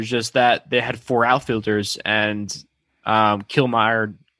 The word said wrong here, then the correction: Kiermaier: where fucking pronounce his Kiermaier. name.